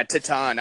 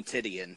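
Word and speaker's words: Titán: Hangman Page and the Young Bucks, the six-man tag champions, versus Dragon Lee, Flip Gordon, and how do you pronounce his name? I know it's like Titan or something. – Yeah, Titan. I just want to call him Titian.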